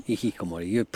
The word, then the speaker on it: hikikomori: A jak by se tedy v této standardní japonštině vyslovilo slovo hikikimori? – In this standard Japanese. – Hikikomori. Hikikomori. Your pronunciation is very good.